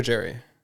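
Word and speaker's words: Gerry: Is it Gary or Jerry? – It's Gary. – It's Gary, but and it's spelled Jerry with a G.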